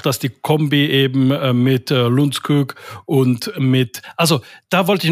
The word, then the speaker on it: Lundskog: Und in Schweden wird halt Johan ausgesprochen. Und das U wird dann, also bei Lundskog, das U wird ein bisschen wie ein kleines bisschen wie ein Ü ausgesprochen, also Lunds. Und dann hinten das O, Kug. Also Johan Lundskog.